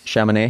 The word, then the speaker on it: Chamonix: How do you say that? France. Chamonix. Chamonix. Chamonix, Chamonix France.